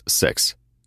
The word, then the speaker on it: Секс: Утром в редакции обнаружил записку у Бида: «Должен срочно кинуть страну, за главного будет ЛС секс». Кто это? Тут меня окликнул грубый мужской голос, обладателем которого оказался новый заместитель главного редактора, Лидия Семеновна Секс. Читается как «Е», а не «Э», как можно подумать.